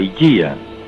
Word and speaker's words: Ligeia: No, porque él tenía otro preferido, que era la Higía. Se escribe Ligeia, como usted sabe.